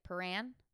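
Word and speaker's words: Prahran: After Rachel left class on March 1st, she met Caroline at the Paran. I said it right? Paran? – Yeah.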